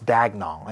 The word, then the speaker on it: Daigneault: Mark Daigneault, yeah. – Is that how you say it? – I think it's Daigneault. – I was like, Daigneault.